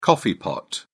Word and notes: coffee pot: British English

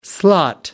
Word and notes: slot: American English

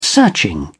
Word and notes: searching: British English

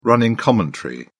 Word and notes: running commentary: British English

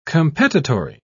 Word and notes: competitory: British English

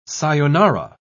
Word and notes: sayonara: British English

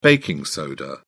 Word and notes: baking soda: British English

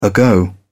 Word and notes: ago: British English

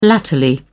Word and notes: latterly: British English

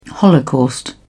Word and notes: holocaust: British English

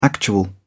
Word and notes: actual: British English

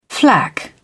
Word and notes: flak: British English

